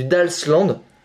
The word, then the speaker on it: Dalsland: Genre tu regardes la carte de la région du Dashland, je le prononce mal, c'est du Dalsland, il y a D-A-L-S-T-A-N-T D.